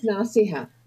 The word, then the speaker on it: Naseha: And the name is Naseha. – Naseha. It is beautiful. I just suck. – No, it's a little difficult. Basically Arabic, so it's a little difficult for even for Indians even to pronounce it.